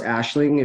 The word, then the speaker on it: Aisling: It's actually pronounced Aisling if you said it correctly.